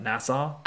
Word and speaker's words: Nassau: Nassau? – I think it's, they say Nassau.